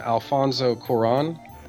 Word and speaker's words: Alfonso Cuarón: Specifically, it was the new director, and I'm going to do my best to pronounce this guy's name, Alfonso Cuarón.